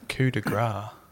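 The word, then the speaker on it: coup de grâce: Now, I just before the show got corrected in my pronunciation of what... – That's pronunciation. – Pronunciation of what I called Coop de Grace. – Coop de Grace. – And what did you call it? – Coup de Grace. – Yeah, coup de Grace.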